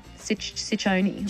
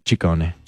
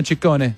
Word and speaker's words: Ciccone: Well, in Italian, it works the other way. So the double C's are hard C's and the singular C's are soft C's. – So Ciccione. – Ciccone. – Ciccone. Madonna Ciccone. God, why'd she drop that? That's good. It's Ciccone.